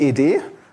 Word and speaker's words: ed: Es gibt noch eine GNU-Interpretation von EX, nämlich ED. Wird nicht X und nicht Ad ausgesprochen, sondern die Buchstaben wirklich einzeln, wie bei VI eigentlich auch.